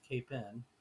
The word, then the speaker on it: Capon: The whole word capon, C-A-P-O-N, that we pronounce not the way phonetically you would think. You would think capon, but it's capin. Because it's a much anglicized Native American word that means medicine waters, healing waters.